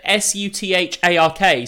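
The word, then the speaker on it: Southwark: it's South Wark in spelling, but I've said it several bad ways. I definitely. What? How would you I always pronounce it Suthark.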